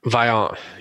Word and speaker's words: Vaillant: Vaillant and Latour.